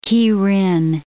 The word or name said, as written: Kirin